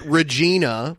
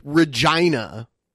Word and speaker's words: Regina: You could have just called it Regina, which is what it looks like, but you had to nope. call it Re- Regina.